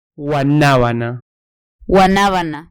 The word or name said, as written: guanabana